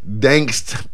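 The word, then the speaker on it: Gdańsk: Dangst, Poland. – What? – G-D-A-N-S-K. But it ain't Gdansk, but it's Dangst, Poland.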